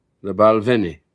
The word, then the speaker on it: The Balvenie: The Balvenie.